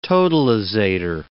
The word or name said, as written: totalisator